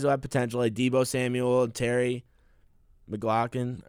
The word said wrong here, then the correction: McLaurin: McLaurin. McLaurin. Yeah, uh-huh. I don't know why I said that wrong.